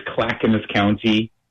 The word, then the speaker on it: Clackamas: Yes, and yes, you did say it correctly. It is Clackamas County.